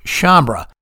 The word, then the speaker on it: Sciambra: Assistant DA Andrew Mumu Sciambra, that's how I've been pronouncing it, is actually Andrew Mumu Sciambra. I'm not going to go back at this point and change anything in prior episodes, but I'll pronounce it correctly from this point going forward. You all have been patient with my numerous mispronunciations in the past, so thank you for that.